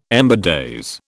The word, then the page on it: ember days: British English